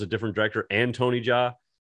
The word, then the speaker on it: Jaa: Is that how you pronounce it, Ja? – Yeah.